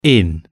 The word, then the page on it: in: British English